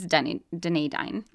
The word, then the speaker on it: Dunedin: It's Dunedin.